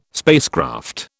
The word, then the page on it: spacecraft: British English